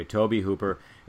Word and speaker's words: Tobe Hooper: Am I pronouncing that right? Is it Tobe Hooper? – I believe so. – Okay, Tobe Hooper.